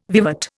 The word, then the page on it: vivat: Indian